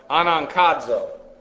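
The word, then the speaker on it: Anankazo (ἀναγκάζω): What you have here is the aorist active indicative of anankazo. A-N-A. And actually what you have there is a gamma, but it's pronounced as a noon, an N, because of the rules of phonetics, and then K-A-Z-O.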